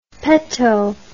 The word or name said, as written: petto